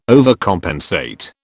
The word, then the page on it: overcompensate: British English